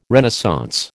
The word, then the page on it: Renaissance: American English